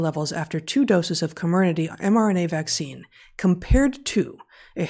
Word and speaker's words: Comirnaty: Furthermore, we observed significantly higher IgG4 levels after two doses of Comirnaty, which I also never know how to pronounce, the Pfizer vaccine. – I thought it was Comirnaty, but... – Comirnaty? Okay, I'll start over. I'll go with Comirnaty.